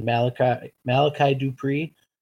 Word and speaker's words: Malachi Dupree: I think beyond that, him and Malachi Dupree, I'm saying it right now, right? – Yep, you got it.